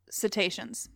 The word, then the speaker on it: cetaceans: C-E-T-A-C-E-A. Yeah, yeah. – Cetaceans. – Yeah, I feel embarrassed. It's pronounced cetaceans.